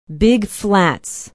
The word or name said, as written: big flats